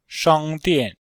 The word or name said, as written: shang1dian4